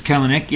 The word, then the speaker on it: Kelenic: And am I saying that right? Is it Kelenic or Kelenic? – I think it's Kelenic. Yeah, Kelenic.